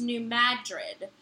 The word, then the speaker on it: New Madrid: let Bonnie know that the New Madrid fault is pronounced New Madrid. Emphasis on mad, not drid, like in the city in Spain.